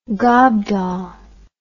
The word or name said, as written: gobdaw